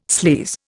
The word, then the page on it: sleaze: Indian